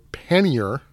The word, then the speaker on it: pannier: Both pronunciations that you commonly hear, pannier or pannier, are both correct. – Okay. – But he pretty much leaned towards the French way to say it, pannier, isn't probably correct.